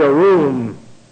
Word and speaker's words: arum: The word in the Greek here in Genesis chapter 3, verse 1, is the Hebrew word arum. Arum. You have this unpronounced aleph, A, and then you have R-U-M. Arum.